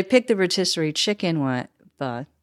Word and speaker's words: pho: Always asking a question, add a question mark at the end of the word pho.